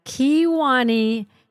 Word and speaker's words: Kewaunee: Well, Brian, thank you for taking the time out of your evening to talk to me tonight about the Kewaunee County Jail Museum.